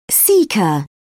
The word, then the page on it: caecum: British English